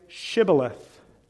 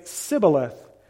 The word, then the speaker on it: Shibboleth: Well, if you want to cross over the river, then say Shibboleth. But the Ephraimites, they were unable to say Shibboleth, and they would say Sibboleth, for he could not pronounce it right.